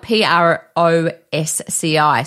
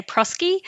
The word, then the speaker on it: ProSci: And lots of people say Prosky. Oh, prosky. I would say Prosky too, yes. Yeah. Through the accreditation process, they um, tell us that is ProSci. ProSci.